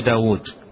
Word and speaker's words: داود: داود، أن داود بواوين داود واوين لكن تكتب واو واحدة واوين تنطق نطقا وكتابة واو واحدة،